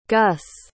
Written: guss

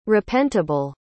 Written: repentable

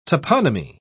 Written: toponymy

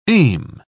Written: aim

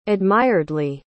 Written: admiredly

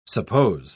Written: suppose

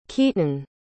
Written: keeton

